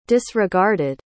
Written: disregarded

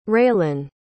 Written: railynn

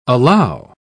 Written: allow

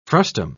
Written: frustum

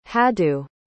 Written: hadu

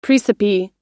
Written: praecipe

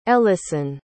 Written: elison

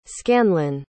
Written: scanlan

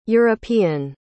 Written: european

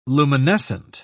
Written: luminescent

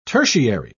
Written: tertiary

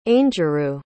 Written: aingeru

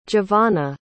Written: javana

